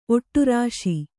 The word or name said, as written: ಒಡರಿಸು